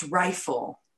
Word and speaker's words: Raefyle: They spelled it R-A-E-F-Y-L-E, pronounced rifle.